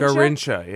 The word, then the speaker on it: Garrincha: Garrincha, yeah.